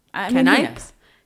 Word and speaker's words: Canipe: I think it's Canipe. It's spelled C-A-N-I-P-E. – Yeah. – But there's no thing on the end. – Oh. – Canipe?